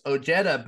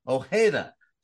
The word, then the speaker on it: Ojeda: Now it's Ojeda, but as I told Richard, you know, that's how he pronounces it, but it is Hispanic in origin. And I always grew up and it was Ojeda.